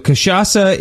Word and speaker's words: cachaça: which is called cachaça. Is that how you pronounce it as well? – That is how it's pronounced, yes. – Okay, good. So cachaça is a Brazilian rum.